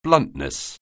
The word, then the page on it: bluntness: anglais